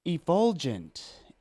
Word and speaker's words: effulgent: I this is another word where I appreciate that you have the pronunciation there because by looking, I would almost say effulgent, but because of the pronunciation, effulgent. – E. – E. That E. It's a hard E.